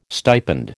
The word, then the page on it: stipend: American English